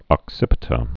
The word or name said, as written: occipita